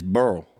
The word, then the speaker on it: Beryl: Literally, when I got home Friday, I'm not going to dig into it, but Hurricane Beryl just came through, and one of our locations had a lot of. – You said it wrong. It's Beryl.